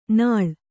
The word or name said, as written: ನಾಳ್